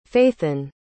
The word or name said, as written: faithan